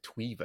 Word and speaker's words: tweva: Yeah, so I was going to say that I was going to correct that name, Tweba. It's actually, we pronounce it Tweba. It's more like the TV in Tweba.